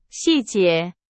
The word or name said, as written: 细节